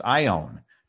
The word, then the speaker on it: aion: This word that we see as age in our Bibles in the Greek, I apologize, Mary, for my mispronunciation, it is pronounced ion. It comes into English as A-I-O-N.